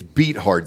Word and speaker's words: Beathard: Bethard. – Oh, Beathard. Sorry, man. You keep mispronouncing that. It's Beathard.